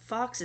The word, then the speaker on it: Fawkes: Fox is Fawkes, not Fox. I always thought of that Fox. Alrighty,